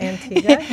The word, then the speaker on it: Antigua: Robin served as the outreach and communications liaison for Crossroads Center at Antigua. Did I say it right? – Antigua, yes.